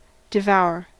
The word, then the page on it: devour: American English